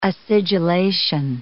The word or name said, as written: acidulation